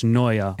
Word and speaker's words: Noija: Their band is spelled N-O-I-J-A, but it's pronounced Noia, like paranoia. – N-O-I-J-A.